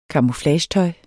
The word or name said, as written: camouflagetøj